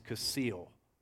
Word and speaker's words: kasil: This word for fool in Proverbs is kasil. I think that's roughly how it's pronounced in Hebrew.